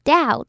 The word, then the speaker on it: doubt: Billion 接下来要注意发音的字是 Doubt Doubt 的 OU 发的是 OU 的音然后中间的 B 是不发音的所以呢就只要念 T 就好了 Doubt, Doubt还有